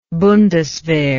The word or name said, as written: bundeswehr